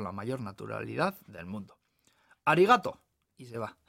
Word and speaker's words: arigato: Pues se dice arigato. Arigato, arigato. Practica por lo bajo poniendo el acento en la segunda A, lo que hace que suene muy gracioso, así que no le digo nada.